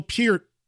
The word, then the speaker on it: Peart: I've interviewed Geddy and Alex countless times, both TV and radio, and even interviewed Neil Peart, and yes, that is how you say his last name properly.